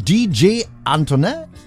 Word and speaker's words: Antoinette: Unter anderem DJ Antoinette, so leicht französisch wird das ausgesprochen.